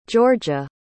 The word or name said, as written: georgia